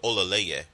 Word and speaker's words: Olaleye: Like Spencer got through it, Olaleye. – Olaleye? – Yeah. – Not Odele? – No, like Olaleye.